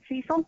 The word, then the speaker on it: Cecil: He pronounced it Cecil, like Cecil the C6 sea serpent.